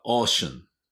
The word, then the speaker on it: ocean: 海は ocean, ocean. これもオーシャンじゃなくてオーシャン。オーね、オー、オー。ちょっと微妙に違うんですね。